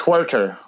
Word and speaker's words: Huerter: Is it Huter? He plays for the Atlanta Falcons. He's 4.5. He's Atlanta Hawks, not Falcons. – Oh yeah. See, I'm in the NFL mode, man. I'm in NFL. See, I can't get out of it. Yeah. – It's Huerter. – Huerter, is that how you say it?